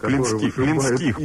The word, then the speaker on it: Клинских: Вот такие вот песни поет группа «Сектор Газа», и в частности Юрий Хой, Клименских или Клименских, как правильно ставить ударение, чтобы я запомнил? – Есть корень такой, Клин. – Клин. – Да. – А, значит, Клинских, прошу прощения.